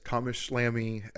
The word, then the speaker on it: Thomas Schlamme: But uh, yes, Thomas Schlamme directed episodes one and two, not any of the other ways I pronounced it.